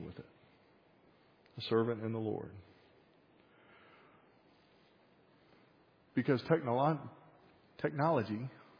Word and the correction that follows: technology: I put the emphasis on the wrong syllable there for a second. Technology can't overcome certain limitations.